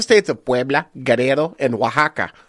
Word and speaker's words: Oaxaca: No, it's Oaxaca. Listen. – Oaxaca. – When you find out something is called Oaxaca, you say Oaxaca a lot.